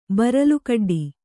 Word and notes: ಬರಲುಕಡ್ಡಿ: Kannada

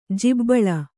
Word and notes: ಜಿಬ್ಬಳ: jibbaḷa

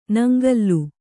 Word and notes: ನಂಗಿಲು: naŋgilu

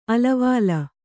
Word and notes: ಆಳವಾಳ: āḷavāḷa

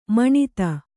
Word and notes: ಮಾನಿತ: Kannada

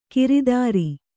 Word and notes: ಗಿರಿಧರ: giri dhara

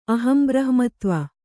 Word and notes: ಅಹಂಬ್ರಹ್ಮತ್ವ: kannada

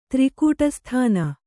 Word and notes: ತ್ರಿಕೂಟಸ್ಥಾನ: Kannada

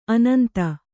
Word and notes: ಆನಂದ: ānanda

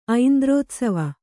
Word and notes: ಐಂದ್ರೋತ್ಸವ: kannada

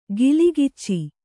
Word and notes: ಕಲಗಚ್ಚು: kalagaccu

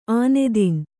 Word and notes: ಆನೆದಿನ್: ānedin

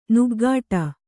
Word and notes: ನಗಾಡು: kannada

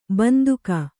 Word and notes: ಬಂದುಕ: banduka

